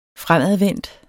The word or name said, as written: FREMADVENDT